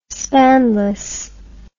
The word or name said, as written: spanless